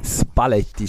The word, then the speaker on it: Spalletti: Spalletti spricht man korrekterweise aus.